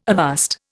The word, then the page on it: avast: Indian